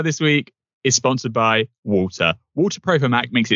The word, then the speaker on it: Waltr: Walter Pro is the third iteration of the popular Walter app. That's spelled W-A-L-T-R.